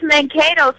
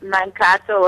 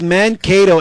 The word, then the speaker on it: Mankato: It's Mankato, so somebody said it right, but at first the girl was saying Mankato or something like that. – And so it's Mankato, and it means stupid state, right, in Sioux Indian? – How did you know that?